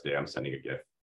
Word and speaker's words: GIF: Do you pronounce it GIF or JIF? – GIF. – Okay, GIF. Good. That's the correct answer.